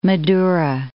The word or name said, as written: Madoera